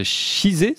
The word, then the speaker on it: Chizé: Vous êtes herpétologue, directeur de recherche CNRS au Centre d'études biologiques de Chizé, c'est comme ça qu'on prononce ? – Oui.